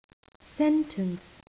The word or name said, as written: sentence